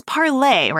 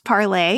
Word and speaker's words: Parler: It's Parler, right? Parler, yeah, is how you say it in French.